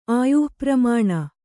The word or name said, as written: ಆಯುಃಪರಿಮಾಣ